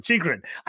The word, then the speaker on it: Chychrun: Chychrun, First, I think uh, oh, I don't know why I always want to call him Chicharon. I do it all the time, and I every time I say it, I know I say it wrong. Chychrun.